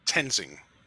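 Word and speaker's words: Toensing: Is it Tonsing? I can never say her name right. – Victoria. Victoria Tensing, I believe.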